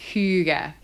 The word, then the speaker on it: hygge: Hug. It sounds like the English word for hug. – Aww. – Which, which is thought to have roots in making oneself snug. So it's pronounced hygge. Hygge.